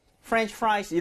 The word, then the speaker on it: French fries: 요거 발음할 때 French하고 fry, French fries 이런 것도 이제 F 발음이에요. 처음부터 그, 이거 앞에도 F, 뒤에도 F인데 입술이 붙으면 안 되겠습니다. 그렇죠? 그래서 French 이렇게 그리고 fries. Right? 바람이 많이 나와야 돼요.